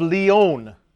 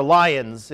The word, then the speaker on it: Lyon: All right, our early church father for today is Irenaeus of Lyon, or Lyons, if you're from America, or from Illinois.